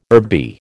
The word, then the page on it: herby: American English